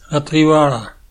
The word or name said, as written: Trivalle